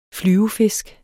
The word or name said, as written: flyvefisk